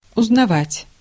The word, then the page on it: узнавать: /ʊznɐˈvatʲ/